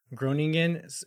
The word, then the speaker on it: Groningen: He signed with San Jose in 2017 from Groningen, I believe is the Dutch pronunciation of it.